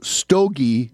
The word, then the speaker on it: stogie: stogie boots. The sight was a wonder, and it was the first lesson in the methods and the costs of war. Stogie boots, pronounced like the cigar, is a coarse, heavy type of a boot.